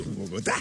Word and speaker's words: Bogotà: In seguito ad una rivolta della popolazione a Bogotà. – Con l'accento sull'A. – Certo, l'ho ben detto, l'accento su Bogotà.